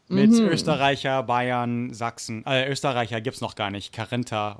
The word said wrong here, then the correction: Kärntner: oder so, aber doch, ja. – Kärntner. Genau.